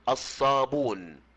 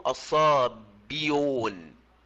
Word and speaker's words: الصابون: تأمل كلمة الصابئون، الباء مكسورة والهمزة مضمومة، فلما حذفت الهمزة أتى بعد الكسرة واو مادية، فهل ننطق بعد الكسرة واو مادية؟ لا ينفع، إذا لابد أن نضم ما قبل الواو المادية، فنقول الصابون ولا نقول الصابيون، هذا لا يصح.